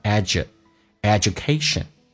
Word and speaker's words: education: 音在美式发音只用发后面一个叫 rudeness, 所以气流阻塞一下，因为前面是一个元音 rudeness, 粗鲁呢 shows, 后面加 s 一般发 z, 除了连音发实际的 z, 一般都会发成短促的 s, shows, poor education, 我们知道这个单词没有 edu, 英式美式都是发 edu, or education, 但重音在后面，经常美式发音把那个 u 发成 a 这个发音叫 education.